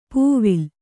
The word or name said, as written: ಪೂವಿಲ್